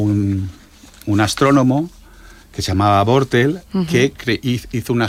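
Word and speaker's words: Bortle: Tenemos herramientas, por ejemplo, la escala Bortel.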